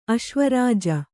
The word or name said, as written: ಅಶ್ವರಾಜ